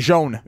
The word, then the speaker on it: Jaune: J-A-U-N? – E, yeah. J-A-U-N-E. Yeah. Jean. Jean is how you say it in French.